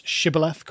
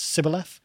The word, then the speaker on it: shibboleth: They couldn't pronounce shibboleth correctly. They pronounced it as sibboleth.